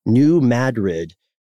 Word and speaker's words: New Madrid: And this is the New Madrid. We pronounced it correctly this time, Matt. – Mm-hmm. Yeah, we did. That's it.